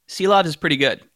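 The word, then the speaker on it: Silovs: Arturs Shilov, I believe. – Shilov. – Shilov, okay. – I think the last S is silent, but don't hold me to that. – And they throw a silent S at you after you get it.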